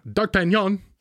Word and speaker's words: d'Artagnan: D'Artagnan. That's how you say it in French.